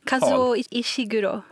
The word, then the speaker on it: Kazuo Ishiguro: Nu är det faktiskt så att en nobelpristagare, Kazuo Ishiguro. Du får korrekta mitt uttal. – Kazuo Ishiguro.